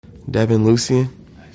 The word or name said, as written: Devin Lucien